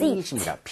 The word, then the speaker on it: receipt: 이거 그나저나 영수증 할때 이거 receipt가 아니라 Receipt. – Receipt입니다. P는 묵음입니다.